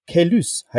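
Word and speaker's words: Caylus: Ja, und ein großes äh, Mysterium der Brettspielszene wurde aufgeklärt, nämlich die Aussprache des Spiels, das vor 20 Jahren den Sonderpreis gewonnen hat, äh, von dem gleichen Autor Cyril de Demag, nämlich äh, Kailus e heißt es wohl offensichtlich. Das scheint die offizielle Aussprache zu sein. Wenn immer alle sagen Kailus e oder Kailü e oder wie heißt es eigentlich?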